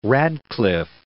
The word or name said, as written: Radcliffe